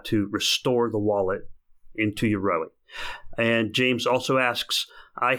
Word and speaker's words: Yoroi: And James says, Hi, can I restore my Daedalus wallet using the Rory wallet? The answer is yes. Of course, I pronounced Yoroi wrong. It's Yoroi. Yes, you can.